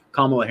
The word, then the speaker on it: Kamala: Kamala Harris, yes.